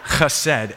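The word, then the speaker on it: chesed (חסד): which means that I can get it pronounced about 12% correctly. So it's chesed.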